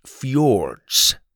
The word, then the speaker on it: fjords: It's pronounced fjords, Grandpa corrected.